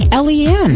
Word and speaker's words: Eliane: Or Elaine. What do you think there? Eliane? I think Eliane. – Yeah, it's spelled Eliane.